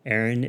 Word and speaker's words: Erin: Aaron is spelled E-R-I-N.